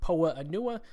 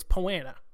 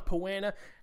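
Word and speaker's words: Poa annua: Notice, by the way, I finally nailed the pronunciation of that word. If you've listened to this podcast for a while, you know I used to pronounce it Poa-Anua. No. I have nailed it. It's Poana.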